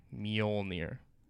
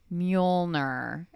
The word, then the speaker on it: Mjolnir: So like basically the the J is is like a Y. – Okay. – Mjolnir. – Like, could they make it harder? I don't understand. Mjolnir.